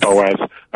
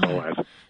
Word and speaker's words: Boaz: Boaz. I know you want to say it like Boaz because of the OA, but Boaz.